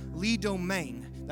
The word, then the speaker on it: Domingue: But Pastor Lee Domain, that's how I'm trying to say it the Cajun way. So Lee Domain, that's how he told me to say his name.